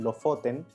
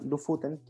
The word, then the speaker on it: Lofoten: Eh, es el archipiélago de Lofoten, o como muchos le llaman Lofoten, porque así se escribe o se podría leer en español, acá lo, llaman Lufuten, que es un archipiélago de islas en,